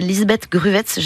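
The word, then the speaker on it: Lisbeth Gruwez: Et aujourd'hui, on s'intéresse à une artiste, Lisbeth Gruwetz, j'espère que je prononce bien son nom. – Très bien, parfait.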